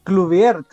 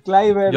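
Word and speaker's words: Kluivert: Sí, eh, eh, este chico, ¿cómo dijiste la pronunciación? Porque yo en, mi, en mi poco neerlandés iba a decir Clubier. Kleiber. Yo soy Kleiber.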